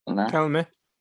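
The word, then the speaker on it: Kelme: But I think for a Kelm kit, I think that's how you say it, Kelm? Kelmer? – Kelmer.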